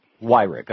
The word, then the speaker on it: Weyrich: Weyrich.